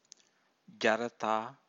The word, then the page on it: jarata: Pali